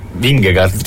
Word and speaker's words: Vingegaard: Secondo me è Vingegaard. – Vingegaard. – Si pronuncia così? Sì, c'è.